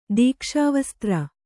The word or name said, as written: ದೀಕ್ಷಾವಸ್ತ್ರ